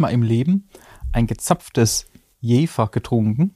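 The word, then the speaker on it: Jever: Aber es ist Jäfer.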